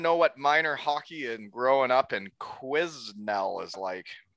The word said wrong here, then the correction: Quesnel: Well, first of all, it's pronounced Quesnel. It's a Quesnel, so it's French.